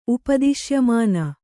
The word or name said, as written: ಉಪದಿಶ್ಯಮಾನ